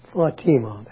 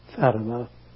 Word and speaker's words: Fatima: As I told the people, Fatima, that's the pronunciation, the favorite daughter of Muhammad.